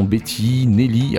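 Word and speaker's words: Betineli: Et euh, on va balayer un petit peu la carrière de quelques chanteuses pour ce premier volet, notamment Betty, Nelly. Alors attention, ça s'écrit B-E-T-I-N-E-L-I, e donc consonance européenne mais pas forcément orthographe.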